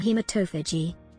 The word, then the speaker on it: hematophagy: human hematopography. – That's pronounced human hematophagy, Scott.